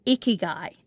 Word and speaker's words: ikigai: Is that right? Ikigai? I think that's how I Googled how to pronounce it. – I mean, that's how I pronounce it, but I don't speak Japanese anyway, so I think that's more or less it.